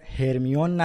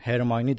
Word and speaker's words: هرماینی: تلفظات دقیقا مثل نسخه بریتانیاییه یعنی ما دیگه هرمیون نداریم هرماینی داریم یا دورسلی نداریم درزلی داریم و